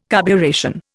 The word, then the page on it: carburation: Indian